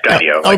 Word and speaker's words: Skydio: And joining us this morning is the co-founder and CEO of Skydio. Do I have that right? Skydio? Is that how you pronounce? – Yeah, Skydio.